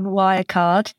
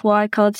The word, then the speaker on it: Wirecard: The documentary is called Scandal, Bringing Down Wirecard. And yes, the German pronunciation is deliberate. It is spelt that way.